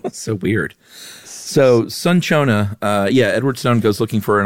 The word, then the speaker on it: cinchona: Is it cinchona? – No, it's neither. That's so us. I just looked up real quick. It says it's sunchona.